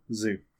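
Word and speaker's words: Zhu: How do you pronounce his last name? It's Z-H-U. I forget how that's... Chu? – Zu. – Zu? – I think. – Zu? Vank Zu? – Yes.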